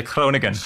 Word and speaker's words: Groningen: And great Dutch pronunciation on the Rijksuniversiteit. That's always hard. – Yeah, it's not so easy to say Groningen, but yeah.